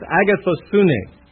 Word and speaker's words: agathosune: No, I know how to pronounce it because it's got an accent. It's agathosune.